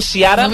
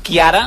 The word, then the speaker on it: Ciara: Dèiem Ciara, però em sembla que es diu Chiara, em sembla que s'ha de pronunciar així. – Chiara, sí.